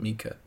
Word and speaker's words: Micah: I think it's pronounced Mika. It's spelled Micah, but people call it Mika.